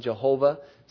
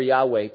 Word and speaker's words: Yahweh: The term Lord there, every time you see the capital L, then the smaller capital, O-R-D, that's what is put in the English for Y-H-W-H in the Hebrew, which is pronounced by some Jehovah, some Yahweh. It's more Yahweh probably than Jehovah because they didn't have the J sound.